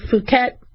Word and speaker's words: Phuket: Don't pronounce it short U. Do the long U just to play it safe, Phuket.